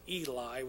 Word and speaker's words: Heli: And Jesus himself began to be about 30 years of age, being the son of Joseph, which was the son of They have it spelled Heli with an H, but we would pronounce it Eli with just an E, which was the son of Mattit, etc., etc.